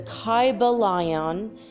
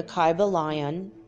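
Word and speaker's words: Kybalion: The name of the book is The Kyber Lion. I'm going to spell that for you. It's K-Y-B-A-L-I-O-N. The Kyber Lion. It's one word.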